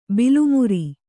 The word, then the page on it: ಬಾಲೆಮರ: bāle mara